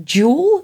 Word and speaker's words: Yule: Apparently, it's pronounced like Jule in Danish and Swedish, which means mm, Christmas.